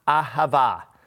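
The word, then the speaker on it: ahava (אהבה): And it's pronounced ahava, ahava.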